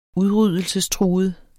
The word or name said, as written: udryddelsestruet